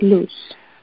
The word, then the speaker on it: Luz: Luz? – Luz? – Yeah, L-U-Z. – Ah, light. – Loose.